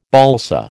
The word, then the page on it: balsa: American English